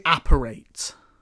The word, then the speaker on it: apparate: And then the second that they like, I don't even know how do you say it. Evaporate, evaporate, apparate, apparate. The second that they apparate as well, like there's a actual shot of seeing how it all happens.